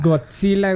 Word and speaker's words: Godzilla: Eh, ¿Cómo se pronuncia? Godzilla. – Godzilla, güey. Godzilla. – Es que caes mucho en el, tema de, de muchas personas tienen el problema del TS, güey.